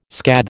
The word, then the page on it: scathe: American English